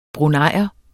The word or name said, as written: bruneier